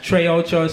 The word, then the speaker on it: Tr3acho: Again, the name is Trey Ocho, spelled T-R-3-A-C-H-O.